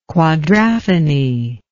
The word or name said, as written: quadraphony